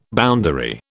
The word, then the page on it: boundary: British English